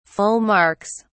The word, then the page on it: full marks: British English